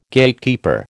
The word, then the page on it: Gatekeeper: American English